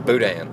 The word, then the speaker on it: boudin: They'll give you some, I'm not going to act like I'm Cajun and try to speak that way, but boudin. And then how you say it? B-O-U-D-I-N, as far as I know.